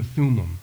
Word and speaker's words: Thummim: with what the Old Testament describes as the Ummim. I'm going to pronounce it like a Mainer. The Urim and the Thummim, that's it.